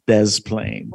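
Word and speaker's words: Des Plaines: Sorry, you need to say this right. It's Des Plaine.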